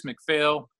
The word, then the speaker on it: McPhail: Again, it's Bruce McPhail. Last name is spelled M-C-P-H-A-I-L.